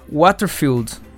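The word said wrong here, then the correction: Whitefield: Espero ter pronunciado corretamente o Waterfield. acho que é o É, é Whitfield. – Whitfield. Isso. Ok.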